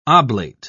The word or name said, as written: oblate